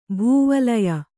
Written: ಭೂವಲಯ

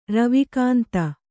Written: ರವಿಕಾಂತ

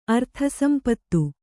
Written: ಅರ್ಥಸಂಪತ್ತು

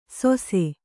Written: ಸೊಸೆ

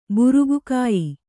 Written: ಬುರುಗುಕಾಯಿ

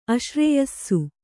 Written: ಆಶ್ರಯಿಸು

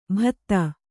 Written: ಭತ್ತ

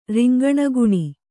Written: ರಿಂಗಣಗುಣಿ

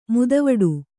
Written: ಮದವಟ್ಟೆ

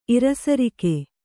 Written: ಇರುಸರಿಕೆ

